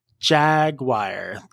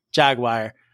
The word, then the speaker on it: Jaguar: Jaguar, that's what it is. – Jaguar.